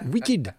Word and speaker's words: Wicked: la casquette de prof d'anglais me fait préciser que Wicked se prononce Wicked.